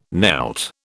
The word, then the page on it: knelt: British English